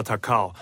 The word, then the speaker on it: 高雄: 然后就是但诶我应该有在节目讲过吧，就是台湾那个以前高雄会叫打狗，就是因为那个京都高雄的日文发音叫 Takao、 oh. – 对，所以台湾人就会把它叫打狗啊，对对对，就是才是因为是高雄的，日本人那时候来台湾说这边是高雄哦，是 Takao 哎，